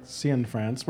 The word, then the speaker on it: Cianfrance: Well, our guest here at the Apple Store Soho has been Derek Cianfrance. Where does your last name come from? – America. – Did I pronounce it right? Is it? – Yeah, Cianfrance. It was Cianfrani, which was the Italian name.